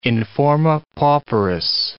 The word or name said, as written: in forma pauperis